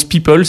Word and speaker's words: PIPL: Elle s'appelle le PIPL, euh, qui d'ailleurs se prononce People.